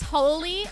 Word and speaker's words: Holy: Like it's So it's Holy, H-O-L-Y, which is how Holy is spelled, _schnit.